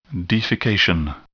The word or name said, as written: defecation